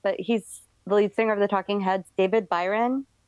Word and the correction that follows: David Byrne: David Byrne.